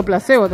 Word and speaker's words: Placebo: No, es que se dice así, yo digo Placebo también.